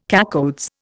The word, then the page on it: cacoethes: Indian